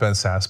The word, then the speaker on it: Sasse: I know his name is pronounced Ben Sasse, but I like to call him Classy Ben Sasse.